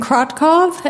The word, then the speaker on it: Krotkov: My name is Jasmine Krotkov, and that's spelled K-R-O-T-K-O-V, and I come to you from the Montana Farmers Union.